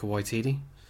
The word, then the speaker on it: Waititi: Uh, directed and written by uh, Taika Waititi. Uh, I'm pretty sure I said that correctly. you uh, did. Well done.